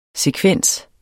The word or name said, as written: sekvens